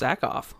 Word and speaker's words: Sackhoff: I think it's just Sackhoff. – Is it Sackhoff? – Yeah, and if not, I mean, we get memes.